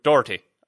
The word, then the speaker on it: Doherty: We have been called up on the pronunciation of that Irishman's name. It's Doherty.